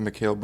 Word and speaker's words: Mikal: And they're going to need a really big jump offensively from Michael Bridges. – Isn't it Mikal Bridges? – I hate this. And why is it spelled like that, bro? – Sure it's Mikal Bridges.